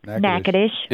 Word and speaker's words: Natchitoches: Natchitoches.